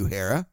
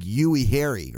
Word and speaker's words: Uehara: Koji Uehara. Uehara. I remember Gary Thorne could never pronounce his name. He used to call him like Yui Harry or something.